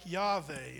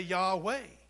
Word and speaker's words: Yahweh: One would be like Yahweh, and it's spelled Y-H-W-H. There's really no W in Hebrew, so it's more of a V sound. And some people, especially in Texas, would say Yahweh, right?